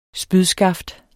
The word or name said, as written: spydskaft